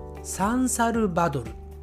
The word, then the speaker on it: サンサルバドル: カタカナにすると同じような発音に見えるのですがやはりアクセントが違うんですよね日本人はこの首都キャピタルのことをこう発音しますサンサルバドル